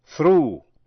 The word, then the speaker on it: through: Chữ O-U-G-H còn đọc là U. Thí dụ, through, T-H-R-O-U-G-H, là qua.